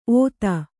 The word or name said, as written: ಔತಿ